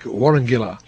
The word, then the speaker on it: Warawangila: In the Oromo language, the ID sounds like Kun Radio Warawangilati. Warawangila is pronounced together like Warangila, so that could be what he was really hearing.